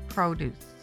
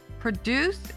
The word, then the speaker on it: produce: Produce is a verb with the accent on the last syllable. Produce is a noun describing a harvest of fruits and vegetables, and the accent is on the first syllable.